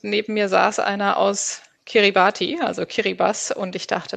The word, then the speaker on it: Kiribati: Kiribati spricht man es aus in der okay, Landessprache.